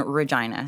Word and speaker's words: Regina: I went to Saskatoon, Saskatchewan for university and then started my entrepreneurial growth in Regina, Saskatchewan, the city that rhymes with fun. – Yeah, I was like, it sounds like vagina.